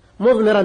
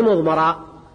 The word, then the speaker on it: مضمرا: إن قطعت مضمرا، مضمرا تقرأ بكسر الميم، ما هي مضمرة، إنما هي مضمرا لأنه قال مضمرا مبتدأ، وعلى هذا ما إعراب مبتدأ مفعول به لمضمر اسم الفاعل.